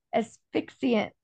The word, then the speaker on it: asphyxiant: Cyanide is a chemical asphyxiant.